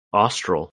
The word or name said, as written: Austral